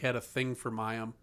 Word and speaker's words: Mayim: So you're gonna Clinton Mayim? – Yeah.